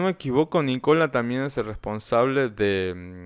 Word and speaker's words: Nicola: ¿Nicola se pronuncia en, italiano Nicola? Nicola.